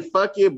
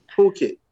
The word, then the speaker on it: Phuket: I used to say fuck it, but I'll just recently put it. Phuket. Something like that.